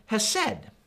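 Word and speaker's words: hesed (חסד): But you got to kind of get it from your throat, right? Sorry about that if you're eating, right? If you're not spitting on a person in front of you, you're not saying it right, right? But it's hesed. And that's kind of literally how it's written in Hebrew.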